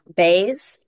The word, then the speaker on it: Bays: Elizabeth Bays, last name spelled B-A-Y-S.